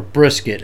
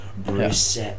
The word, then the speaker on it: Brissett: Or Brisket, as you call Brisket. – It. – Brisket. Well, he's earned me to pronounce his name correctly now. I apologize, Jacoby Brissett.